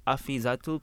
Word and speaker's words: Afiezatul: In the middle I have Afiezatul.